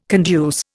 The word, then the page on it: conduce: Indian